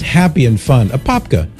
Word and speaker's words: Apopka: And Jeanette in Apopka. I hope I'm saying it right, Mary. Does that sound right? Apopka, Florida. – That sounds perfect to me. – Right on. Apopka, Florida.